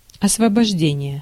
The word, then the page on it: освобождение: /əsvəbɐʐˈdʲenʲɪjə/